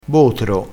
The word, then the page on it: botro: dialettale